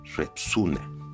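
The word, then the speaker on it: Retsina: Se escribe Repsina y se pronuncia Repsuna.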